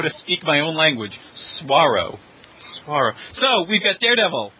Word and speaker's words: Swaro: So the characters are Daredevil, Ghost Razor, Pyroman, and I'm sorry, how would you pronounce it? – Swaro. Just think S-W-A-R-O.